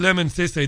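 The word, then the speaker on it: Lemn Sissay: Before I take the next call, check out Lemon Sissé. That's spelled L-E-M-N. Sissé, S-I-S-S-A-Y.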